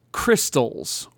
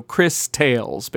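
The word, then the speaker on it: Cristales: So next up is Crystals, or Crystales. It's spelled C-R-I-S and then capital T-A-L-E-S. So Crystales, basically.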